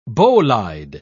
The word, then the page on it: bolide: British English